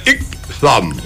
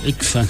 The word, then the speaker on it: Itzan: Hola, Julián, le pueden saludar a mi sobrino Ixam Samuel, 5 años, I-T-Z-A-N. Si va a pronunciar bien, tenés que pronunciar Ixam. – Ixam.